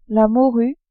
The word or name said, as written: morue